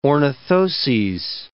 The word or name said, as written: ornithoses